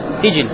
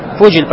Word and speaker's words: Фуджиль: Фуджиль. Здесь говорят как? Фиджиль. Здесь говорят с касрин под фе. Правильно говорить с даммой под фе.